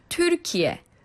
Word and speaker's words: 튀르키예: 그러니까, 이게 저희가 그냥, 들리는 발음이 트르키에라고 얘기를 하지만, 실제로 발음하기는 어떤 건가요? 한번 해보실 수 있을까요? – 네. – 예. – 튀르키예.